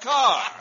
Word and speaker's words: Kerr: And I include Douglas Fairbanks Jr. and Deborah Kerr, pronounced Carr.